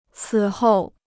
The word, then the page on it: 此后: cǐ hòu